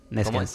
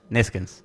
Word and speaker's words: Neskens: Me ayudan con la denominación del apellido, porque capaz que lo dije mal. Neskens. Johan Nekes. – Neskens. – ¿Cómo es? – Neskens. – Neskens.